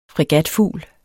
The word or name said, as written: fregatfugl